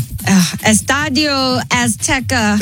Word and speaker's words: Estadio Azteca: Estadio Azteca. – Thank you. There you go. – Did I say that right? – Yeah. Aztec Stadium.